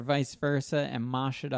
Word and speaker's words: mosh: So it was called MASH, but they pronounced it MOSH.